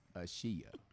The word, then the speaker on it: Ashia: Shout out to Asia Thompson. – Right. And I'd be like, who is that? – So one day, Dwayne's like, B, the wife say her name is not Asia. It is Ashia.